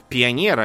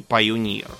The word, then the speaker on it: Пионер: Тут такая проблема с этими всеми зондами, что некоторые, вот, например, почему мы пионер произносим как пионер, а не пайонир, как было бы по-английски. Э, Потому что у нас есть такое слово.